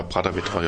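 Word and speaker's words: Prāta Vētra: Und zwar haben wir uns jetzt, wir sind wieder bei Lettland, was Musik betrifft, für Prata Vetra entschieden. Habe ich das so richtig ausgesprochen? – Ja, genau. Also ich denke, ja, Prata Vetra, ja, genau.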